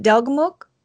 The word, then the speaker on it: Delgamuukw: And I'm not sure if I'm pronouncing it right, but is it Delgamuukw? That's right. Yeah,